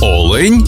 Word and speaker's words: олень: однині і в множині наголос падає на перший склад – олень та олені.